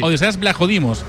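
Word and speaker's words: Odysseas Vlachodimos: Es Odiseas, realmente es, sí, el griego, sí, sí. Es Odiseas Blajodimos.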